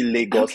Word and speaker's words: Lagos: I was waiting for you to pronounce Lagos. I was seeing, because that's something that most people always mess up, but you actually like did well than most people. So it's actually Lagos, okay.